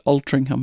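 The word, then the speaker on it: Altrincham: And his pronunciation of Altringham was also absolutely atrocious. Altringham. So I said, it's actually pronounced Altringham. And he's like, where's the G? No idea. No idea where the G is. But that's how it's pronounced. And then he was talking about what the company actually did.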